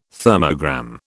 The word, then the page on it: thermogram: British English